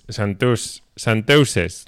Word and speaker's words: chanteuse: Y otra de las grandes chanteuses, no sé cómo se dice, cómo se pronuncia. – Chanteuse.